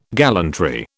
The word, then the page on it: gallantry: British English